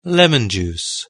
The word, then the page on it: lemon juice: British English